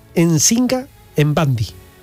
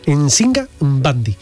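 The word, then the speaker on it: Nzinga Mbandi: Pero fijaos, se llamaba Nsinga Mbandi. Así hay que pronunciar las M, ¿eh?, y las N. Nsinga Mbandi.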